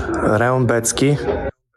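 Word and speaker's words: Rębecki: When it's M-A-T-E-U-S-Z, and Cody obviously is graphically representing this for people on the video side, but if you're listening, the last name is spelled like Rebecki, R-E-B-E-C-K-I. So can you play the file again? I thought Mateusz Rebecki, but then you listen to the file.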